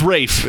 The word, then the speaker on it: Ralph: Well, this is spelled Ralph, but... – But it's Rafe. – It's Rafe, yes.